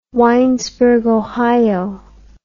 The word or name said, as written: winesberg ohio